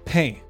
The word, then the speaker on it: pain: So Requiem, it's pronounced pain. – Le pain. – Pain. – And le is the equivalent of the. – Yeah. – So it's an article, it's not part of the word. It's pain.